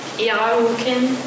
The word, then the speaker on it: Jehoiachin: Jehoiachin. – Very good. Very good. You pronounce the I like a Y, which is precisely how it should be pronounced.